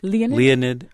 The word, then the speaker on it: Leonid: Leonid McGill.